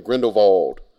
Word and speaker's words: Grindelwald: And I realize we've all been mispronouncing his name, as in the movie they constantly call him Grindelwald.